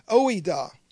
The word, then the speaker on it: oida: that word know God is oida. O-I-D-A is how you spell it, oida.